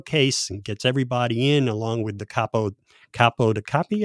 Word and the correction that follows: capo di tutti capi: How do you say that? – Capo di tutti capi.